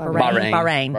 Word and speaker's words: Bahrain: My brother Nathan is in the last year of his tour with the Navy, and he's stationed in Bahrain. Is that said it right? – Bahrain.